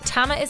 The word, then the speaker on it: Tamar: My name is Tamma Broadbent. Tamma is spelled T-A-M-A-R. Nobody can ever find it because it's such a like unusual name, but it's Tamma Broadbent.